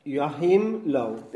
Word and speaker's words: Joachim Löw: Joachim Löw.